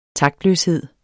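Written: TAKTLØSHED

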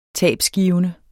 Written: tabgivende

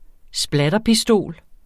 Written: splatterpistol

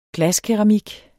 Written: glaskeramik

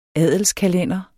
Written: adelskalender

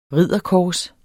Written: ridderkors

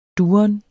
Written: duren

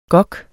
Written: gok

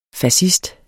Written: fascist

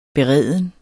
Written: bereden

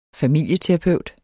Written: familieterapeut

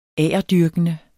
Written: agerdyrkende

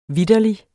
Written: vitterlig

